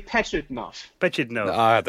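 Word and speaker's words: Pajitnov: And another one was with, I'm going to try and say his name, Alexey Prigidnov. – Prigidnov. – I can never say the creator of Tetris. – No, it's actually Alexey Petchidnov.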